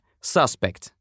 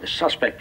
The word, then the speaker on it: suspect: What about the the eyewitness report of suspect being a a man in clown mask? Если мы ударение поставим на второй слог, получится глагол suspect — подозревать.